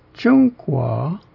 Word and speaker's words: 中国: The last syllable is a diphthong of U and O together, with the O being rising in pitch, and that's why it's pronounced 中国, meaning China.